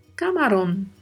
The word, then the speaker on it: camarón: Pronúncia aí atenção com a letra A, tá? Camarón. Camarão. O A é mais aberto, né?